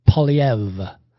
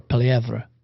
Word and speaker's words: Poilievre: It's Poilievre. Pierre Poilievre. That's how you say it properly. Anyway, or Poilievre, but it's Poilievre.